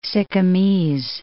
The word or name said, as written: Sikkimese